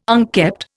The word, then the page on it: unkept: Indian